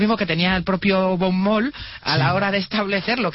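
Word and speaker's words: von Mohl: Para von Moll, que yo lo pronuncio en alemán, von.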